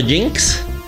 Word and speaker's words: Jynx: Un personaje llamado Jinx. Se escribe J-Y-N-X.